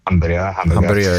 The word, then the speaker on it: Hamburgö: Ja, men det är på Hamburgö, tror jag uttalar det rätt. Hamburgö, ja.